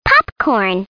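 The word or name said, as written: popcorn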